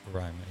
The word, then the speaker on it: Raimann: Tampa Bay getting Bernhard Raimann. Is that your pronounce his name? – Is he a guard? – He's a tackle from Central Michigan. Tackle or guard? – Raimann, yeah.